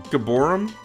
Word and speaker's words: Gibborim: G-I-B-B-O-R-I-M. How was that pronounced? I don't know. Gibborim? Gibborim sounds fine.